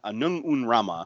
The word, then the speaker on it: Anung Un Rama: I always say that wrong. I want to say Sun, but it's not. Anung Un Rama, destroyer of all things.